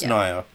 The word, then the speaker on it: Naya: Would you say Naya or Naya? – Naya Rivera. – It is Naya.